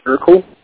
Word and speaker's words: Ircle: Basically, for Mac OS X, there's something called IRCLE. I believe it's pronounced Urkel. And Colloquy, C-O-L-L-O-K-U-Y.